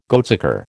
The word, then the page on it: goatsucker: American English